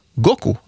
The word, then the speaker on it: Goku: Dagen lär vara utvald på grund av att på japanska kan siffrorna 5 och 9 uttalas Go och Ku. Goku alltså.